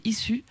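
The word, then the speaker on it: Issuu: Il n'a pas encore de site Internet, mais il a trouvé une plateforme d'hébergement qui s'appelle Issu. Ça s'écrit I2S2U.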